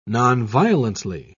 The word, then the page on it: nonviolently: British English